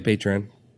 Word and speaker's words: Patreon: So, like, a funny story about podcasts, and I know, like, Patreon. Or is that a... Did I pronounce that right? – Yeah, Patreon.